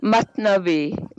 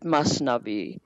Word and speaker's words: Mathnavi: And she's studied Rumi for 20 years, delving into his complex, multi-volumed Mathnawai. I hope I said that right, Kamala. You'll have to correct me if I didn't. – Mathnavi. Mathnavi, or some people call it, pronounce it Masnavi.